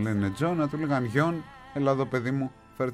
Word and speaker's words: Jon: Θα ήθελα όμως, αν μπορείτε με ελληνικούς χαρακτήρες, το J-O-N που μου στέλνετε για το Lord, πώς προφέρεται, Γιον ή Ζων.